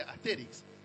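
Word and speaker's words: Astérix: Después había gente que tomaba muy literalmente el acento en la E y te decía Asterix. Y, después cuando aprendes francés te das cuenta que no, que es Asterix.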